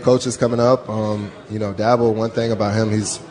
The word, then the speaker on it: Dabo: And Jerron, what's it like playing for a guy like Dabo Swinney? Am I saying it right? – Dabo Swinney.